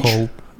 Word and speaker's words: Whole: Foods, вот так пишется. – Угу. – И там, значит все типа локо, все локо, да, W, w H. Hall. Да, в холл, да, а, да ага, в, холл, Whole Foods.